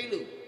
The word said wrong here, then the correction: Pelau: Pelau. Like chunky thing. Say like a Chinese. Rhymes with now. – Lau. – Pelau.